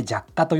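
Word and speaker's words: 若化: 若く化けると書いて弱化と読む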